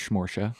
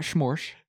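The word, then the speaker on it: Porsche: And it rhymes with Schmorsha. Not Schmorsch. – Right. – Schmorsha.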